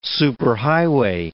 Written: superhighway

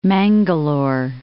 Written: Mangalore

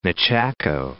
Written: Nechako

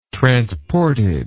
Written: transported

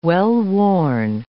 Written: well-worn

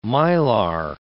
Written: Mylar

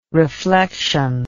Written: reflexion

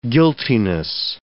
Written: guiltiness

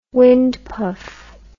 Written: wind puff

